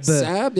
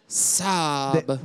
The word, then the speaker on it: Saab: Saab, yeah? – You're not saying it right. – Saab.